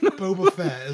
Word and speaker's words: Boba Fett: At least I learned that it's actually Book of Bubba Fett is going to be shorter. – Bubba Fett? – I said it wrong. That's called redneck Boba Fett is what I'm getting.